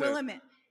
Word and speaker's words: Willamette: Willamette, thank you. – Wait, how do you pronounce it? – Willamette. – How did she pronounce it? – Willamette.